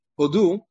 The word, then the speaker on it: Hodu: Hodu, okay, first of all, it's pronounced, the dalet is rafui. The dalet is without a dagesh. Hodu, which means give thanks. Right, and it's also milera. That's in the tefillah, which means that the emphasis is on the second part of the word.